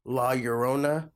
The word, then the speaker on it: La Llorona: Like what you were saying with like La Llorona.